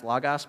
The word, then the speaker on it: logos: So the word for word that John is using here is logos. It's actually pronounced logos, but that's annoying, and I'm not going to do that.